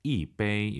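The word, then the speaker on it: 一杯: 숫자 1 다음에 일성이 오면은 숫자 1이 사성으로 바뀝니다. 이베이 이렇게 발음하세요.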